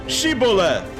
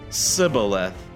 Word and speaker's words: Shibboleth: If he said, "No," they would say, "Say the word Shibboleth." The men of Ephraim could not say that word correctly. They pronounced the word Sibboleth. So if the man said Sibboleth, the men of Gilead knew he was from Ephraim.